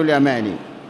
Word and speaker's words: اليمان: حذيفة ابن اليمان، اليمان بفتح الياء والميم، فتح المثنى التحتية وتخفيف الميم وآخره نون، هكذا حذيفة ابن اليمان أو ابن اليماني.